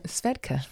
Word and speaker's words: Svedka: Shveka? – Svedka. – Svedka. – Yeah. – Sorry, Svedka. I didn't say it right.